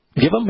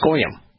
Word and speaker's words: goyim: Well, probably not, because it's goyim. And you know why it's goyim? Because it's G-W-Y-M, and the W is pronounced O. That's why it's goyim. It ain't givim.